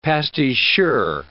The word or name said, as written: pasticheur